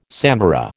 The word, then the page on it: samara: American English